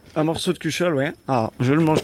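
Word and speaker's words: cuchaule: Cuchol, voilà, pour bien prononcer. – Ouais, c'est ça. – Cuchol avec l'accent broyard.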